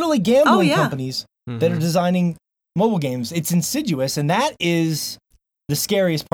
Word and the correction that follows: insidious: So, did I say it wrong, Josh? Is it Insidious? – Insidious, Okay. Insiduous I see Josh going to type now, and then I know he's going to be funny.